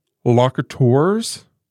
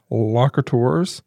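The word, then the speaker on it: lokators: They were called locateurs, is what I would pronounce that word as. But it's L-O-K-A-T-O-R-S, locateurs.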